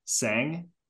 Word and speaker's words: Tseng: He has his last name is spelled T-S-E-N-G, Tseng. – Tseng, yep.